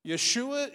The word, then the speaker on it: Yeshua: Yeshua is, is the, the pronunciation, it would be like Joshua.